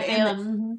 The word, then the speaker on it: Balaam: Balaam, yeah, Balaam.